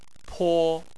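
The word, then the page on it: po: tone 1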